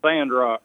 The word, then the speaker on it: Sandrock: Did I say that right? Is that Sandrock? – Yeah, Sandrock.